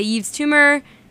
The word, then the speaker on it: Yves Tumor: And before that was Romanticist by Yves Tumor. Yes, that's how you pronounce it. I should know that I take French.